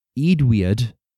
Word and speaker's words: Eadweard: So, I'd looked into Eadweard. That's how you actually pronounce it.